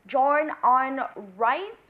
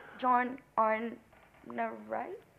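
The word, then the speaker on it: John Arne Riise: This week we have a man with the killer right foot, John Arn Rice, with us. Did I say that right? John Arn Rice? – You said the name correctly, but.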